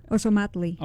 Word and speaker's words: Ozomatli: Ozomatli. Is that how you say it? – Ozomatli. – Okay, all right, there you go, perfect.